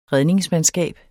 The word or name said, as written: redningsmandskab